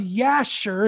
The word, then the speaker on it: Jasher: This book was discovered and translated from Hebrew into English by a British scholar in 1840, and it's called the Book of Jasher, is how you pronounce it. You might even want to call it Jasher, J-A-S-H-E-R.